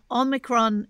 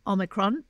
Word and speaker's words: Omicron: I heard this morning on the ABC a pronunciation expert from the BBC in England saying that Omicron is pronounced Omicron, but he said, but also it is acceptable to call it Omicron and various other things.